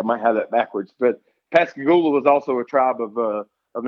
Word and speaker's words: Pascagoula: The town I was born in is Pascagoula, Mississippi.